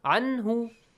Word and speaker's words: anhu: Noon has to be pronounced as noon sound. Anhu. Do not say anhu.